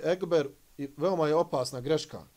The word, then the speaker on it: ekber: Riječ ekber se izgovara kratko i brzo, bez rastezanja i odugovlačenja. – Allahu ekber. Isto, neispravno. Allahu ekber.